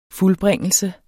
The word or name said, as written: fuldbringelse